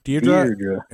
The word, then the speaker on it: Deirdre: Deirdre.